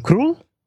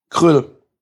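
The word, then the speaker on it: Krul: Und ich als Gegner im Finale von euch muss natürlich sagen, was der, wie wird er ausgesprochen? Krull? – Krüll. – Krüll.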